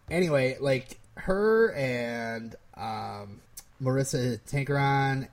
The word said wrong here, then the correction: Tancharoen: and I think isn't it Tancharoen? – Yeah, I can't pronounce things.